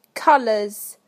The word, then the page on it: colours: British English